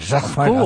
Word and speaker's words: Jacquemart-André: Jacques Marc André.